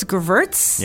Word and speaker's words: Gewürztraminer: Gewürztraminer. Ah, Gewürztraminer. – You can call this Gewürz. – Yeah. – Or you don't have to pronounce, because in German, the W is a V sound.